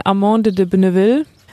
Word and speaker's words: Amande de Beneville: Wie spricht man dein Pferd überhaupt aus? Einfachstens, ist, man sagt Mandy, ist ihr Stallname. Ähm, nun bin ich ja auch kein Franzose, aber die sagen Amande de Beneville.